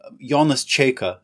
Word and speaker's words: Jonas Čeika: Jonas Čeika, as it's pronounced, is my pen name. It's Lithuanian.